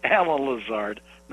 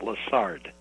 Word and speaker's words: Lazard: Clearly it says Alan Lazard, not Lazard.